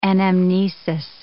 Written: anamnesis

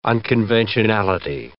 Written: unconventionality

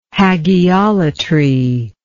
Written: hagiolatry

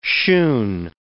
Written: shoon

